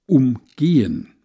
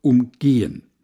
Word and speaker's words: umgehen: Es gibt das Wort umgehen, erklärte er und betonte es auf der zweiten Silbe. Also einen Stau oder eine Baustelle umgehen. Und es gibt umgehen. Betonung auf der ersten Silbe.